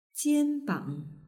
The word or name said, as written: jianbang